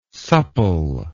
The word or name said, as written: souple